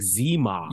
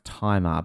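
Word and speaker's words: Tzi Ma: I love the actor guy, but like, I'm seeing here you pronounce it Tai Ma. – I always thought it was like Zima. – Yeah, so did I. But like, according to this thing I'm reading, it's pronounced Timer.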